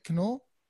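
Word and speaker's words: Knull: So welcome, James Canole, right? Canole? – Yeah, that's right.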